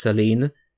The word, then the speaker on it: Silene: And my current laptop, the host name is, I think it's pronounced Saline, S-I-L-E-N-E.